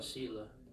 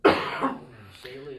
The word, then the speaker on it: Selah: I call it Selah. Selah is the way you say it. – Selah. I think probably if we were going to go with the Hebrew pronunciation, we would emphasize the last syllable.